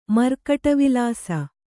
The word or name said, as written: ಮರ್ಕಟವಿಲಾಸ